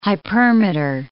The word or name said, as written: hypermeter